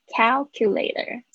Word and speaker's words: calculator: calculator 这个注在讲这个发音的时候特别注意一下，听起来有点像 cow 的声音。